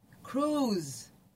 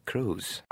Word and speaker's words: Cruise: continuano a non sapere come si pronuncia correttamente il nome dell'attore di Mission Impossible. – Che sarebbe? – Tom Cruise. – Cruise. – Ah, Cruise.